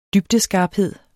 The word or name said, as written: dybdeskarphed